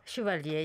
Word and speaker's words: Chevalier: You became the very first Korean graphic novelist, comic artist to receive France's prestigious cultural honor, the Chevalier. Is that the right pronunciation? My French is terrible. – Chevalier, yes. – Chevalier, yeah.